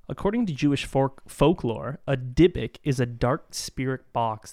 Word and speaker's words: Dybbuk: Dybbuk. Dybbuk.